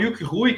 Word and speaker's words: Yuk Hui: Então, Yuk Hui, que a gente fala, é a pronúncia do nome dele em cantonês.